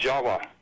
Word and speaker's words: Java: Furthermore, appending the suffix of T-I-S, the word Atlantis, is also spelled T-I-V or pronounced T-I-W. The V's are pronounced as W's in Dravida, such as in Java. The word in Java, the island of Java, you know, is pronounced Jawa. Actually, Indonesians will pronounce it Java, not Java like we do.